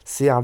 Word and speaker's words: Seär: Serre lui-même, ça s'écrit S-E-A accent R et lui-même lui-même comme lui-même en fait. Hein. C'est pas très compliqué ça, mais le CR c'est SE a tréma R.